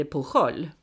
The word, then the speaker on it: Pujol: Så då blir det på jol. Just det. Mm. Så det är skillnaden mellan spanska och katalanska. – För jag, när jag fick höra att det uttalades Pujol, då blev jag väldigt tveksam till att döpa honom till det.